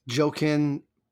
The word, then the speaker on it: Joaquin: Okay, so Joker, Joaquin Phoenix. He took it because his name starts with a J. – Joaquin Phoenix. – Yes. I think that's how he actually pronounces it. Just like it's Ralph Fiennes.